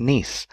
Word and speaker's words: Nice: A Nice biscuit, pronounced Nice, like the name of the French city.